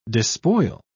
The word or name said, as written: despoil